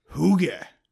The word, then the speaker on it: Hygge: Your mispronounced Higg. – I mispronounced what? – Higg. Your philosophy of Higg. No, you've got to say Hygge.